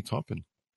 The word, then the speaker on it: Taupin: What was it like working with Sir Elton John and Bernie Taupin?